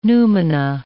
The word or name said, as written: noumena